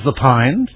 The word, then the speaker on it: Lapine: Is it James Lapine for the 25th annual Putnam County Spelling Bee? – Very good. Now, can you spell Lapine? – L-A-P-I-N-E.